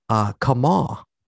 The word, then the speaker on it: Kamau: Also, the guy I've been referring to as Colossus number one for the past couple of weeks, Kamau, K-A-M-A-U. Kamau, like W. Kamau Bell is how it's spelled.